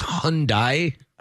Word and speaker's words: Hyundai: Well, and it's pronounced Hyundai. – Hyundai Unicorns. – Oh, not like that, but it's just called Hyundai.